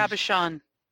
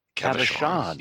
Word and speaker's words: cabochon: So in hindsight, well, not hindsight, trial and error, I didn't find the right glue for the round, how do you pronounce it? Carbicon? Cabicons? Cabs? – Cabochon. – Cabochon. Yeah. – Cabochon.